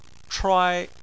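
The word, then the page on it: chuai: tone 1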